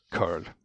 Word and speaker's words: cURL: Har det allt, alltså hur, hur uttalar man, har det alltid uttalats curl? Eller har det... – Jag har alltid uttalat det curl.